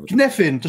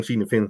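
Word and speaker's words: Cynefin: Knefin, zo spreek je het uit, toch? – Dat heb ik ook. Ik heb ook heel lang Cinefin gezegd voordat ik erachter kwam inderdaad, dat je officieel Knefin moet zeggen.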